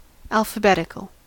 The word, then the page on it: alphabetical: American English